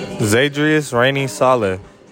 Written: ZAYDRIUS RAINEY-SALE

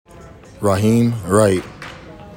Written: RAHIM WRIGHT II

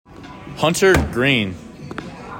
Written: HUNTER GREEN

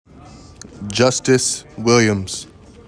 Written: JUSTICE WILLIAMS

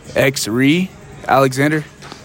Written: XE'REE ALEXANDER

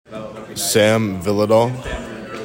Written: SAM VYHLIDAL

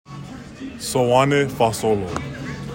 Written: SOANE FAASOLO

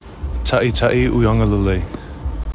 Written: TA’ITA’I UIAGALELEI

